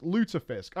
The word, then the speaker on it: Lutefisk: Pronounced Lutefisk. I was correct.